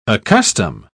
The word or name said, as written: accustom